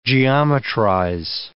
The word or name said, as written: geometrize